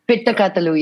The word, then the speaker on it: Pitta Kathalu: Okay, now let's come to uh, um, Pitta Kathalu. I hope I've pronounced it right. Uh, Pitta Kathalu, yes.